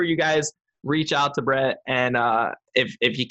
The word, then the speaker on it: Bret: It's Bret B R E T with one T.